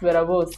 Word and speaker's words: Berabose: So it's pronounced Berabose.